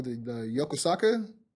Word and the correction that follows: Yokosuka: cutter. I, yeah, it's a Yokosuka. – Yokosuka, like yeah.